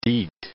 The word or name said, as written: deet